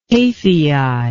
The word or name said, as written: Taphiae